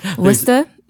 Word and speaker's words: Worcester: There's two ways. Worcester, which is like the Worcester way if you're from Massachusetts, or Worcester. – Okay, Worcester. – So you don't say the A, or you don't say the ER.